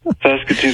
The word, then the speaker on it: Saskatoon: Saskatoon? Saskatchewan? Sass and Sasquatch? What? Okay. I just go the other way. That's all I know. – Saskatoon, Saskatchewan.